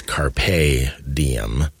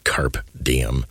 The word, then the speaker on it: carpe diem: It's carpe diem, not carpe diem.